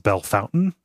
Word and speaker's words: Bellefontaine: It's spelled Bellefontaine, but it's pronounced Bellefountain. – Oh, that's horrible. – Yeah, we have a town that is R-U-S-S-I-A.